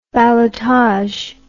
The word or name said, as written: ballotage